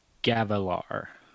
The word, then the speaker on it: Gavilar: I'm going to go Gallinar colon. – Hold up. Whoa. Back up. – Wait, did I say it wrong? – Yep. Give him the pronunciation, or give him how you would say it again. – Okay. – Gavilar. – Okay, so it's just Gavallar.